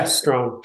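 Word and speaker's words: Strachan: How did he do that? Because it's a CH in there. It's like, come on, that can't be pronounced like that. – Yeah, I don't know. It's a Celtic name, I think I've heard him say, but somehow it gets pronounced Strawn.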